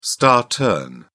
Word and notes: star turn: British English

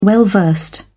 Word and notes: well-versed: British English

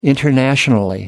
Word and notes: internationally: American English

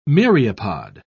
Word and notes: myriapod: British English

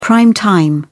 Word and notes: prime time: British English

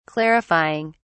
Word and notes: clarifying: British English

